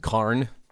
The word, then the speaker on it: cairn: cairn. Is that how you say it? – Cairn. – Is it cairn or carn? – Cairn. – Something like C-A-R-N.